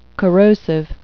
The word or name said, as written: corrosive